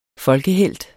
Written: folkehelt

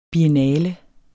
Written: BIENNALER

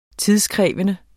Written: tidskrævende

